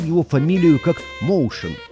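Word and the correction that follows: Motian: Потом великий знаток джаза, живущий нынче в Бостоне, Сабирджан Курмаев, написал мне, что Пол армянских кровей и что его фамилия и звучит по-армянски Матьян.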